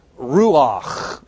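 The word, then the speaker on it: Ruach (רוּחַ): It actually has two syllables in it. Ruach. Everybody say Ruach. And just watch your neighbor in front of you. That's like Johann Sebastian Bach. That's that last sound.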